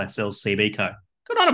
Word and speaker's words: CBCo: That's what it is, is it? CBCo. – That's how they pronounce it.